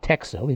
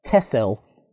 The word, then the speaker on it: Texel: The moment I arrived on the island, I was told it is not Texel, even though it's spelled Texel. It's pronounced Tessel, and the Tessel, and the X is supposed to be some kind of medieval version of SS.